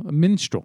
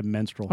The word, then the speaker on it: minstrel: Minstrel. Yeah, that's what I said. Minstrel. – No, you said menstrual. – Oh, why would I fucking say menstrual? I know what menstrual is. – You can't pronounce minstrel.